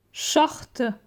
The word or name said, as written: Short